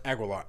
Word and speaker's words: Aguilar: Aguilar.